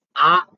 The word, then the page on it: a: Sanskrit